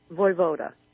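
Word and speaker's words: Vojvoda: Am I pronouncing your last name correctly, Vovoda? – Voivoda. – Voivoda. – Voivoda. Huh? – We weren't sure what to do with the J.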